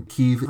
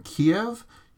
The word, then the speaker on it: Kyiv: I also actually blew most of my normal editing time making sure I was pronouncing Kyiv right, and if you think I should be saying Kiev, you should note that that's not how Ukrainians themselves say it, that's the Russian way,